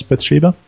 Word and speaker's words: Bathsheba: How do you pronounce Bathsheba? – Bathsheba.